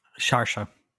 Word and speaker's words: Saoirse: Saoirse Ronan? – Sharsha. – There you go.